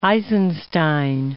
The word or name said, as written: Eisenstein